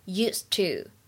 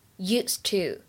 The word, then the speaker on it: used to: 中间这个部分当中呢，有一个 used to 当中呢，可以有一个完全失去爆破的现象，所以呢，可以读成 used to。 used to.